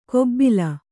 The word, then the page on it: ಕೊಬ್ಬಿಲ: kobbila